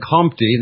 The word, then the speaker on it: Comte: In such passages, we saw how in Comte, this is C-O-M-T-E, August Isidore Marie François Xavier Comte, C-O-M-T-E, I just say it phonetically so that you can understand it. It's pronounced like you're swallowing your tongue.